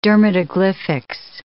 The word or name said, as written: dermatoglyphics